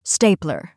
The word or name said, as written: stapler